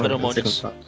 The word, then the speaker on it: feromônios: Acho que é feromonos dela. Feromonos não, feromônios.